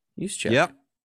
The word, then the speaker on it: Juszczyk: Juszczyk? – Juszczyk.